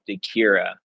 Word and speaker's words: Dechiara: Yeah, so my last name is Dechira, and it's spelled Dechiara.